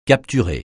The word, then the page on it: capturer: francais